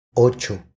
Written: Ocho

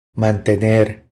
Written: Mantener

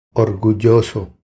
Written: Orgulloso

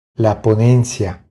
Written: La ponencia

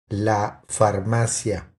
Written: La farmacia

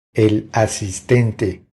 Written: El asistente

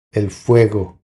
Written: El fuego

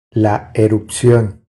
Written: La erupcion

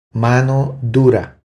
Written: Mano dura

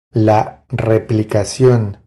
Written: La replicacion

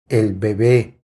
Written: El bebe

